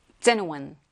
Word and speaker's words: genuine: 요 발음 기호 한번 보시면서 볼까요? genuine, genuine. 앞에 첫 번째 강세가 오게 됩니다.